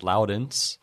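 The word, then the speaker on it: Laurens: His name is Lawrence, uh, which the Dutch way then is pronounced Loudens.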